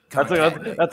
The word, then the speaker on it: concatenate: Concatenate.